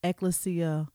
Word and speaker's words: Ecclesia: Apostle is the pastor of Ecclesia Ministries. Now, am I saying that right, Apostle? – Yes, ma'am. Dead on. You didn't butcher it.